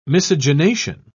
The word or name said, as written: miscegenation